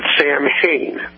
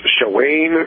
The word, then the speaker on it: Samhain: And last night, they were talking about a ancient festival named, I believe Dale pronounced it, Samhain. – Mm-hmm. – And a caller called up and corrected him and said it was pronounced Shawane or something like that.